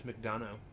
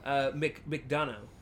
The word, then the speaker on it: McDonough: Neil McDougal. Actually, it's McDonough.